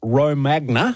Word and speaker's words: Romagna: I'm glad you did Romana. – That one. Or Romana. – We tend to put the emphasis on the wrong syllable here. – Or as we would say here, Romagna.